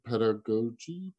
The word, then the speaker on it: pedagogy: Pedagog pedagogy, pedagogy. It's la pedagogy. – Pedagogy. It's pedagogy.